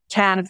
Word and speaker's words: Cannes: Katica, let's talk a little bit about the Cannes. Did I say that right? Film Festival. – Oh, yeah. The Cannes Film Festival.